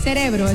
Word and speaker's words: cerebro: Pronuncia cerebro. Pronuncia más. Es decir, cerebro. – Abre más la boca. – Celebro. – Eso. – Cerebro.